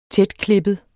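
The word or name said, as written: Tætklippede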